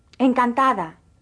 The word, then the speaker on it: encantada: Listen carefully again to the pronunciation of the D in this word. – Encantada. Encantada. – The D, when in between vowels, is pronounced like the TH in the English word the.